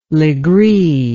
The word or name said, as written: Legree